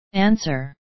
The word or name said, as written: answer